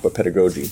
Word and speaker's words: pedagogy: I don't know, is that how you pronounce it? Mm-hmm. Pedagogy? Mm-hmm. But it's pedagogy.